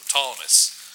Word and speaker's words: Ptolemais: The P is silent, okay? So Ptolemais.